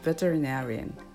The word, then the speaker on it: veterinarian: Veterinarian なーにアクセントがきます。